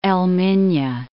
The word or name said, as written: Al Minya